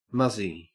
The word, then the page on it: muzzy: Australian English